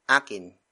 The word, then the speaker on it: akin: Akin. Akin. Akin is spelled as A-K-I-N.